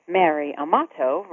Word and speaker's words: Amato: Mary Amato rhymes with cherry tomato, if you say tomato the British way.